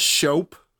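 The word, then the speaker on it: shoppe: I think it's technically pronounced shope. – Yogurt shope. – When it's spelled like that, but I don't know because I know Gavin makes that joke in this.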